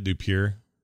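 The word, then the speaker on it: Dupieux: Oh, is that how you say it? Dupier. Dupier. Oh, he's from uh, France.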